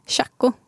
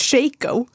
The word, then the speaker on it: Shaco: hur uttalar du det? Chaco. Jag också! Och alla skriker på mig och bara nej det är faktiskt Shaco. Ja, nej, men jag säger också Shaco.